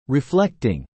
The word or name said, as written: reflecting